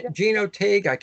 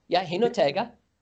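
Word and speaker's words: Jinotega: Gino Tega. I'm sure I'm pronouncing it wrong. – Hinotega.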